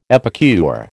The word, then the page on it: epicure: American English